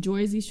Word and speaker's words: Jersey: No, say it like a person from there would say it now. – Joyzy Shore. – There we go.